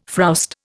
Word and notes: frowst: Indian

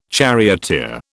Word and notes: charioteer: British English

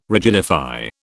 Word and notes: rigidify: British English

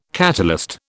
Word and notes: catalyst: British English

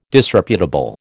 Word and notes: Disreputable: American English

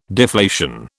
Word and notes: deflation: British English

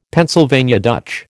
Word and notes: Pennsylvania Dutch: American English

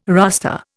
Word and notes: rustre: Indian

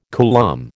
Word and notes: coulomb: American English

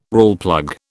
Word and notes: Rawlplug: British English

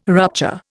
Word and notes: rupture: Indian

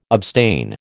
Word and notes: abstain: American English

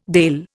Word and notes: Dàil: Indian

